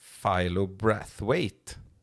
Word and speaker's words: Philo Brathwaite: Philo Brathwaite. Yes. Korrekt uttal på den, eller? Tycker jag. Det var splendid.